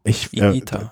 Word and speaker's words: Higuita: Higuita? Das I, das H spricht man nicht mit Higuita. – Ja, aber wo ist denn das U? – Ja, das ist auch stimmlos.